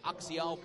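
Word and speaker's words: Axiel: There's two down with Delgado still on first base for Axial Plaz. Axial Plaz, his name almost spelled like you'd think it was pronounced Axel.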